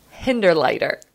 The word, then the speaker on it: Hinderliter: So I'm very grateful to have Kristen Hinderleiter.